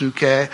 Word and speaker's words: psyche: It's pronounced psuche, but it's the word psyche. And it just means your mental side, your inner person.